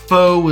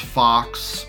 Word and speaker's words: faux: Also, Clown World told me that faux is not pronounced fox. Thank you. You're right. I was wrong. Thank you for correcting me. Faux. I'll try to remember that.